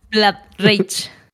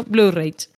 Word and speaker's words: Blood Rage: Blood Rage es el nombre correcto porque sangre, pese a que son dosos, no se pronuncia Blood. Pero toda la vida yo le he llamando, años y años, desde que salió Blue Rage.